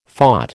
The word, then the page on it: phot: American English